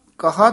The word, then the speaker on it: केहो: तुम कहा तरण हार। – पौड़ी साव केहो तेरा वास है केहो उच्चारण करना है केहो नहीं कह देना के ला हाहे होड़ा ला के साव केहो तेरा वास है।